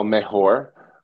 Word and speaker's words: mejor: It's called I think El Mejor, Mejor, did I say it right? – Oh, you said it right.